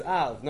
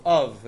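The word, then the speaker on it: Av (אָב): And the last word you say is Av. No, it's Av with the kamatz.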